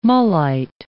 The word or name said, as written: mullite